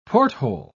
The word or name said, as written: porthole